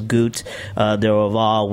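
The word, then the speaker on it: Goût d'Orval: They call it the pronunciation is Goût de Orval, which is basically the essence or taste of Orval, which is what pretty much the Brettomyces is, what does cause that change.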